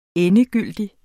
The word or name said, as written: endegyldig